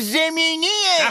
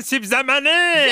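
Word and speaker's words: سیب‌زمینی: نه، تو داری اشتباه تلفظ میکنی. اسمش سیب زمینیه. – آخر بهش میگن سیب زمینه.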